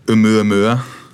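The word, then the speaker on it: Oumuamua (ʻOumuamua): Yeah, well, I think it's pronounced Oumuamua, and it was an asteroid that has reached our solar system from interstellar space.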